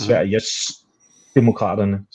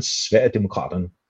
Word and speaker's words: Sverigedemokraterne: Sverigedemokraterne, ikke Sveriges demokraterne, som det oftest bliver udtalt, men Sverigedemokraterne, som har fundet sin pardang i Danmarksdemokraterne måske.